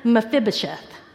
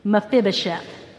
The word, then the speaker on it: Mephibosheth: You pronounce it Mephibosheth. Okay? Say it with me. Mephibosheth.